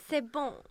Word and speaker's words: C'est bon: Hur, va, hur uttalar man det där franska? Jag är dålig på franska. – Eh. Eller nu har den ju varit släppt ett tag, men... Mm. – C'est bon.